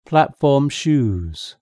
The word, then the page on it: platform shoes: British English